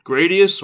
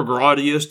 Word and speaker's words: Gradius: Gradius, or Gradius, depending on how you want to pronounce it, is one of the most classic and seminal shooters of all time.